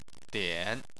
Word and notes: dian: 3

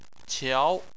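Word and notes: qiao: tone 2

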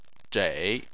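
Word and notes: zhei: tone 3

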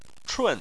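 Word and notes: chun: tone 4

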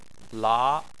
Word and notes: la: 2

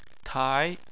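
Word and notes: tai: tone 2

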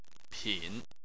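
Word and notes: pin: tone 3

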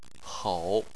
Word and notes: hou: 3

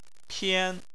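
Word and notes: pian: tone 1

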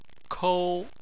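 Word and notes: kou: tone 1

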